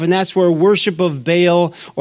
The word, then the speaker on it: Baal: Baal worship, or Baal, as some pronounce it.